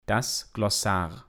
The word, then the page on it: das Glossar: Proper